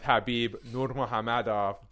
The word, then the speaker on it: Khabib Nurmagomedov: Man, I alluded to it earlier on when we were talking about Khamzat, but Dana White has another meeting planned with Khabib Nurmagomedov. Don't at me, that's how you pronounce it.